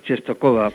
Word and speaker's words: Częstochowa: Częstochowa. Creo que es así como se pronuncia. – Sí, yo he oído más Częstochowa, pero bueno, también la conocen como la Virgen Negra, creo.